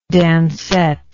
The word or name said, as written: dancette